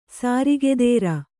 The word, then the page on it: ಸ್ರಗ್ಧರೆ: sragdhare